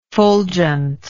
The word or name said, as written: fulgentness